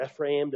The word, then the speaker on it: Ephraim: And so when you hear Ephraim or Ephraim, depending on how technical you want to be and pronounce it, that stands for the northern kingdom.